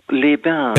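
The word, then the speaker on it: Évian-les-Bains: Evian les bains. Habe ich das richtig ausgesprochen? – Les bains. – Les bains. – Evian les bains.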